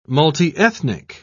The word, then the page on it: multiethnic: British English